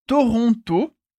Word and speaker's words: Toronto: Et attention, Toronto é a pronúncia em francês de Toronto.